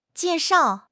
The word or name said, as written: jie4 shao4